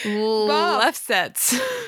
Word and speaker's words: Lefsetz: How would you pronounce that last name? – Lefsetz? – Bob Lefsetz. – Does that sound right? – Yeah, we're just going to call him Bob. – Bob. – Even though we're not on first name basis. Oh, now I have to say it again. Who wrote in his Lefsetz letter. – Lefsetz. I guess it's, I think you're adding some syllables.